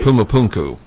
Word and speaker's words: Pumapunku: I don't know if I'm saying that right. – Pumapunku. – Thank you.